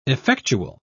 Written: effectual

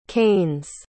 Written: keynes

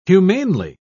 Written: humanely

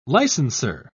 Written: licensor

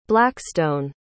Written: blackstone

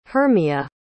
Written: Hermia

